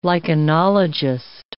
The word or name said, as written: lichenologist